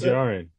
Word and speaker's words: Din Djarin: Is it pronounced Din Djarin? Is that how you pronounce Din Djarin. Din Djarin, yeah.